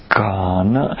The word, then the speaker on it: ghana: The Pali technical term for this is ghana, and the word is spelled G-H-A-N-A,